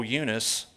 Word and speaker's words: Eunice: this is the Hick, Arkansas way of pronouncing this, oh, Eunice. And I'm persuaded that's in thee also.